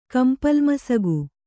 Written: ಕಂಪಲ್ಮಸಗು

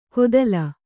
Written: ಘೊತ್ತಲ

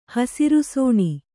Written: ಹಸಿರುಸೋಣಿ

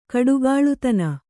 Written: ಕಡುಗಲಿತನ

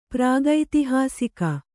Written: ಪ್ರಾಗೈತಿಹಾಸಿಕ

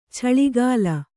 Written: ಚಿಲುಕೂಲಿ

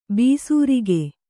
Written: ಬೇಸರಿಕೆ

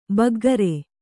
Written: ಬಗ್ಗರೆ

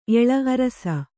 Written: ಏಳು

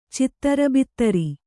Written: ಚತುರ್ಭದ್ರ

